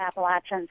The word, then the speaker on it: Appalachia: Either pronunciation is technically correct. but in the southern Appalachians, we say Appalachia.